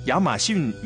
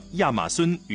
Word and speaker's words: 亚马孙: 后来呢，很多的南美洲的翻译的音呢在逐渐的改，比如说我们之前曾经说过的亚马逊雨林，现在标准的读音叫亚马孙雨林了，啊，不叫逊了，叫孙。